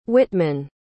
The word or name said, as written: whitman